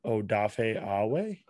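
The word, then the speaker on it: Odafe Oweh: I might butcher this, but Odafe Awe? – I think you got that one.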